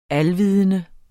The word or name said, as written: alvidende